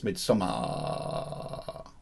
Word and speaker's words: Midsommar: It's not Midsommar, it's Midsommar.